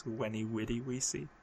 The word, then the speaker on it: veni vidi vici: Veni vidi vici is weni widi wici.